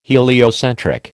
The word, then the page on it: heliocentric: American English